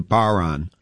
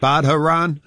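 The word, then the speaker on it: bodhrán: the Irish drum is a baron, and it's pronounced, or it's spelled B-O-D-H-R-A-N. So people are always saying, you playing that Badharan? Illin pipes.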